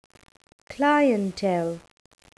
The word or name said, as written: clientele